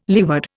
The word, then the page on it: leeward: Indian